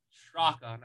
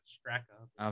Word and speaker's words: Straka: Straka, not Straka.